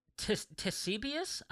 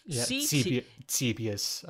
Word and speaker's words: Ctesibius: I think it's Ctesibius, I believe, is what it is. There's a C at the beginning. I'll just spell it. – Ctesibius, I think it would be it.